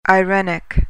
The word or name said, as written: irenic